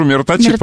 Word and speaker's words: мироточит: Дело в том, что мощей-то много, но мироточит одно. Вот, я вчера был... – Можно я скажу мироточит, по-моему, ударение? Да, ударение мироточит. Да. Мироточит.